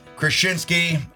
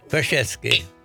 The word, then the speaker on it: Krasinski: Jack Krasinski. – Krasinski. – What 100? Did I pronounce it wrong all the time? – Krasinski. Yeah, there you go.